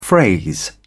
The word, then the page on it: phrase: British English